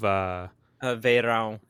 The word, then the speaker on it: Verão: Maybe when the slowed versions of Verão. It's the Portuguese word for summer, and a lot of people don't know how to pronounce it.